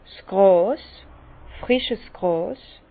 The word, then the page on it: 's Gràs, frisches Gràs: Bas Rhin; Herrlisheim